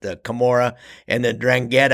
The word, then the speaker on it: 'Ndrangheta: This is going to be about the Drangheta in Australia, and I think I spelled I pronounced that right, didn't I, Cam?